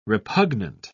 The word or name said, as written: repugnant